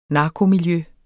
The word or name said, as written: narkomiljø